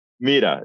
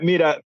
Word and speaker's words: Mira: If you speak Spanish, it's like Mira, yeah. Mira. – Mira. Well, hablo español, so it's Mira Weinstein. Okay. All right, Mira.